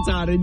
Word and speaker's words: Katahdin: Mount Katahdin. How do you say it? – Mount Katahdin. You said it. You said it right, but it's because you questioned it.